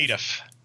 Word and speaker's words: Nedeff: So, and it's N-E-D-E-F-F and it's pronounced again, Nedeff? – Nedeff. Nedeff. – That's so Nedeff. – Knee, deaf.